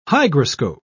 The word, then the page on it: hygroscope: British English